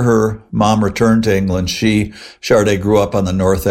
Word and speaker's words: Sade: Now, when you first heard of her, probably back in the 80s, you probably didn't know, like myself, how to pronounce her name. It's spelled S-A-D-E, pronounced Chardé.